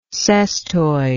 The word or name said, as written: cestoid